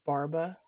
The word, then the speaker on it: Barba: First name is John, which is spelled J-O-N. And then the last name is Barba, which is spelled B-A-R-B-A.